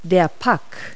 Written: puck

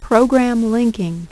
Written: Program Linking

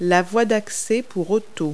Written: driveway

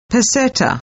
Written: PESETA

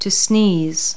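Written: sneeze

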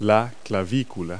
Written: clavicle

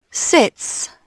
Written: sits

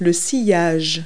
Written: wake

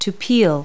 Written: peel

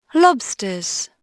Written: lobsters

